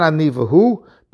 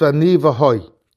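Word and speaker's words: Ani v'hoi (אני והו): But Rav Moshe, in the Shas Tshuvas Igros Moshe, Oyer Chaim Chelek Hei, Simel Amates, says that the proper way of pronouncing it is not Ani v'hu, but it says that it's Ani v'hoi.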